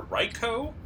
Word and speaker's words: Raikou: So, just a clarification point, I listened to a clip from the anime, and the pronunciations are Raikou, Entei, and Suicune.